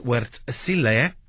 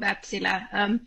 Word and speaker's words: Wärtsilä: Joining us is GM for Market and Financial Analysis at Wärtsilä Energy. Welcome. Did I get the name right there with all of those umlauts on it? – Thank you for having me. Yes, that was good pronunciation of Wärtsilä.